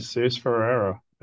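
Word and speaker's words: Jesús Ferreira: How do you say his name? – Jesus Ferreira. – Yeah.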